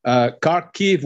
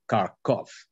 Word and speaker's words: Kharkiv: Kharkiv would be like the Ukrainian, you know, pronunciation, but we all say Kharkov, Soviet style.